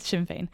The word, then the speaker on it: Sinn Féin: They all pronounced it differently. – Yeah, well, it's Sinn Féin.